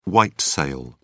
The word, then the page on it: white sale: British English